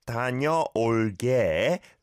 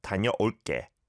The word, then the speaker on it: 다녀올게: And the way it's spelt, right? 다녀올게. It's not a 상기역 on 게, but it is pronounced like a 상기역, right? 다녀올게, 다녀올게. All right, that's an exception to a rule in Korean, I suppose, which doesn't exist that often. Usually it's said how it's spelled.